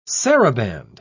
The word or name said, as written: saraband